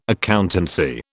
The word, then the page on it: accountancy: British English